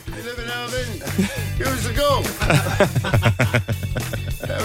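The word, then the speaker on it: Irvine: Irvin. It's pronounced Irvin.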